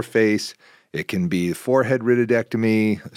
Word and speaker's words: rhytidectomy: And the proper term is rhytidectomy, which is spelled R-H-Y-T-I-D, which is a righted, which means a wrinkle, and an ectomy means it's being removed.